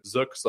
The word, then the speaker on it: Zook: So yes, I will be Jason Zook / Zook, however you'd like to pronounce it.